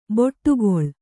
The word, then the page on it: ಬಿಡುಗೊಳ್: biḍugoḷ